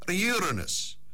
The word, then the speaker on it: Uranus: Uranus, in 1781.